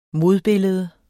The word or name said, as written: modbillede